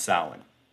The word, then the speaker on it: Samhain: We should just call it Samhain because that's the way it's spelled. It's so weird that it's pronounced Samhain.